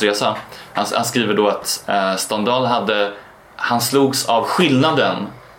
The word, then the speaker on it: Stendhal: Han heter Stendhal. – Stendhal, inte Stendhal.